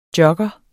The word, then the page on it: jogger: [ ˈjʌgʌ ]; [ ˈdjʌgʌ ]